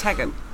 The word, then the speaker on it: Teigen: But even because Chrissy Teigen, as everyone calls her. – It's Tegan. – Even she was mispronouncing it, her own name.